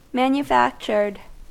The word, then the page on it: manufactured: American English